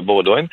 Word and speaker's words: Bowdoin: Alors, oui, ça s'écrit, disons, B-O-W-D-O-I-N. On pourrait le prononcer Baudouin, effectivement, mais j'ai l'impression que les gens là-bas, euh, en tout cas de ce que j'entends, le prononcent euh, Baudouin.